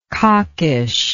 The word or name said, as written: cockish